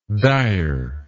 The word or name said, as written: direness